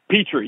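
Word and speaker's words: Pitre: Petre, yeah, Petre, like okay, the Petri dish.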